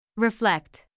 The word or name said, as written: reflect